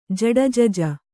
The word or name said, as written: ಜಡಜಜ